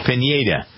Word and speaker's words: Pineda: Now, apparently this pitcher, Michael Pineda, is that how you pronounce his name – Pineda.